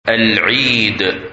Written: Eid

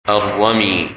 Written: Ramy

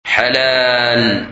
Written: Halal

